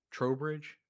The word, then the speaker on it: Trowbridge: I feel like Trowbridge could Trowbridge, is that the correct pronunciation? Yes.